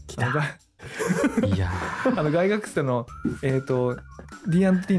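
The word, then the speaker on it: Gygax: 英語で発音するとガイガックスの方があのその音に忠実らしい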